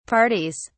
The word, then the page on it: parties: British English